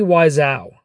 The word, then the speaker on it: Wiseau: That's actually Tommy Wiseau.